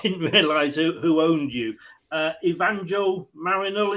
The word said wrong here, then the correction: Marinakis: is it pronounced? I'm useless. – Evangel Marinakis.